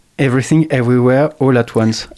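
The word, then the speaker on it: Everything Everywhere All at Once: T'es allé au Cinoche et puis t'es allé voir un film américain qui s'appelle Everything, Everywhere, All at Once.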